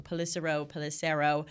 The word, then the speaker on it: Pelissero: I always say his name wrong. Pelissero. We also confirmed it here in-house.